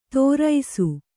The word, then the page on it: ತೋರೈಸು: Kannada